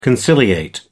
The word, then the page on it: conciliate: American English